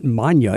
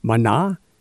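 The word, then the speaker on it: Maná: There's no accent on the end, so it's not Manya. It's Mana, because there's accent Mana, because the accent is on the A.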